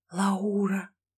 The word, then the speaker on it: Лаура: Лаура, Лаура, Лаура. Я несколько раз прошептал ее имя, ставя ударение на букву «У».